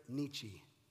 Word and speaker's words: Nietzsche: But you actually, gave a really interesting quote from an atheist philosopher named Friedrich Nietzsche. Is that correct? Am I saying that right? – That's right.